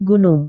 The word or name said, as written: gunung